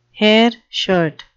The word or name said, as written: hairshirt